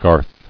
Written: Garth

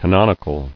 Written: Canonicity

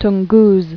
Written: Tunguses

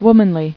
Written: Womanly

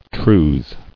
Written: Trews

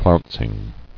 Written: Flouncing

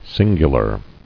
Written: Singularly

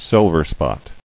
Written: Silverspot

